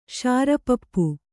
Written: ಶಾರಪಪ್ಪು

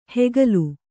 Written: ಹೆಗಲು

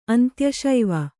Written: ಅಂತ್ಯಶೈವ

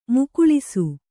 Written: ಮುಕ್ಕುಳಿಸು

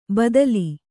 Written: ಭೂತಾಲಿ

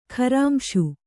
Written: ಖರಾಂಶು

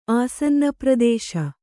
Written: ಆಸನ್ನಪ್ರದೇಶ

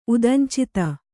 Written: ಉದಂಚಿತ

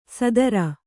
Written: ಸೂದ್ರ

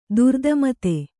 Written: ತಾರತಮತೆ

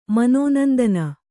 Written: ಮನೋನಂದನ

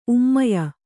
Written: ಉಮ್ಮಾಯ

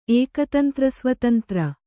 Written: ಏಕತಂತ್ರಸ್ವತಂತ್ರ

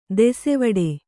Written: ದಸಿವೆಟ್ಟು

